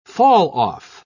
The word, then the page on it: falloff: British English